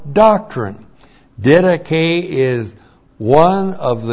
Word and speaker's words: didache: D-I-D-A-C-H, long E. Didache.